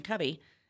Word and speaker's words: Covey: Well, and I'll tell you, I'm actually intrigued because it says there's a foreword by Stephen Covey. Do I say that right? – Yes. – Covey? – Yes.